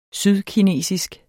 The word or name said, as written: sydkinesisk